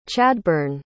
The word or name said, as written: Chadburne